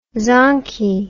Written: zonky

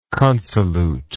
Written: consolute

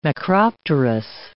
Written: macropterous

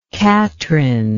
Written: katrine

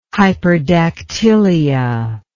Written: hyperdactylia

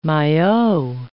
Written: maillot

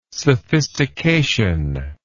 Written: sophistication

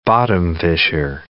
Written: bottom-fisher

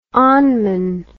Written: onmun

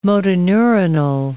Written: motoneuronal

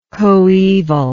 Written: coevally

